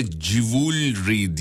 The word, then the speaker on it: jewelry: Bu arada jewelry diye okunuyormuş onu da söyleyelim.